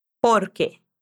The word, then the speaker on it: porque: Porque. Porque. Quando a gente está dando uma resposta, a parte mais forte é o por.